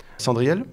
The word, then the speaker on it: Sandrielle: Ça s'écrit comment ? – Cendriel ? – Oui. – S-A-N-D-R-I-E-L-L-E.